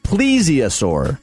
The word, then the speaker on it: plesiosaur: And the correct pronunciation, Brandon Leonard said, is plesiosaur.